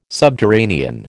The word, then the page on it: subterranean: American English